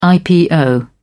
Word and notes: ipo: British English

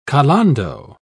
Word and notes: calando: British English

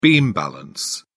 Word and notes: beam balance: British English

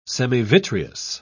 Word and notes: semivitreous: British English

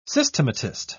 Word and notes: systematist: British English

